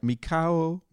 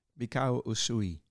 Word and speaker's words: Mikao Usui: His name was, hopefully I pronounced this correct, Mikio Usui. – Yeah, you almost got it right. Mikao. – Mikao. – Mikao Usui.